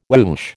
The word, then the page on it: woosh: American English